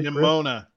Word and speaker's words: Nimona: Nimona.